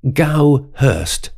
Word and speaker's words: Goudhurst: So I thought, is it Goudhurst or Goudhurst? So I looked it up, and it's Gowhurst, with D in the middle of the name, silent.